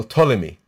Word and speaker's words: Ptolemy: One version is in is in is in Masichet Sofrim, and it talks about the king Ptolemaeus, uh, Ptolemy, right? Talmeus is in Greek. It's written with a P, with an unpronounced P in the beginning.